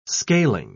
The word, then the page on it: scaling: British English